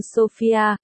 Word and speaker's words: Sophia: Cô nghe thấy chính tiếng mình, và không thể tin vào cái mình vừa thốt ra, Sophia, chữ này phát âm như Sophia, Langdon gật đầu, phải.